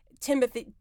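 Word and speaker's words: Timothée: Timothy actually is how you pronounce it.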